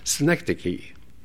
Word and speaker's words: synecdoche: More probably, this is a case of what is known as synecdoche. Synecdoche, I'll spell it for you. S-Y-N-E-C-D-O-C-H-E.